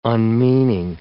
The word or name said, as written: unmeaning